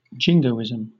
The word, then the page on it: jingoism: British English